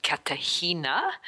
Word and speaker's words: Cartagena: But basically it's Australia via Auckland, then to Buenos Aires, and then down to Colombia to a place called Cartagena. – Oh. – The G is pronounced with a H.